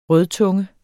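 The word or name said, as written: rødtunge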